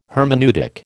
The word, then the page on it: hermeneutic: American English